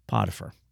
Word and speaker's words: Potiphar: If that's not bad enough, then he's made a slave in the house of Potiphar.